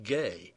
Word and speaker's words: G: I think it should be pronounced Helmut Gay in German, but let's stick with G.